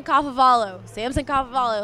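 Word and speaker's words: Kafovalu: I was doing something for a show on campus called CU Sports Mag, but I pronounced it Samson Kafaluau. It was so bad, and I was just like, and right before I went on camera, I was like, Samson Kafavalu, like 50 times, and then went on camera and just said Kafaluau.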